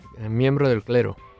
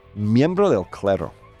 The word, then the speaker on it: miembro del clero: Clero? How would you say it? – Miembro del clero. – Miembro del clero.